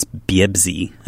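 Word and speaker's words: Biebzi (بيبسي): Arabic doesn't have a letter P, so to speak, so it's a letter B, and so it's Biebzi, as it's spelled on the bottle.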